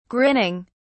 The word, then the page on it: grinning: British English